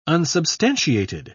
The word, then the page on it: unsubstantiated: British English